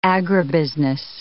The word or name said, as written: agribusiness